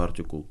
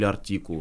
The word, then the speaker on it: артикул: А что такое артикул? Или артикул? Вот мне кто-то поправлял тоже сегодня у нас день ударений, да?